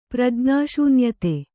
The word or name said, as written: ಪ್ರಜ್ಞಾಶೂನ್ಯತೆ